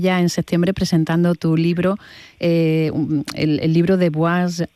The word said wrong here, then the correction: Boise: Es Boise.